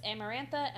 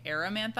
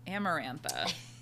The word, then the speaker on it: Amarantha: I said, Feyre meets Amarantha and a bargain is struck. – Mm. Also, I realized I was saying Amarantha's name wrong. I've been saying Aramantha. I thought the R and the M were switched.